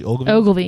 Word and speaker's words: Ogilvy: Am I saying that correctly? – Ogilvy. Yeah.